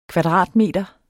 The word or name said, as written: kvadratmeter